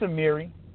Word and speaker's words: Amiri: It's Amiri.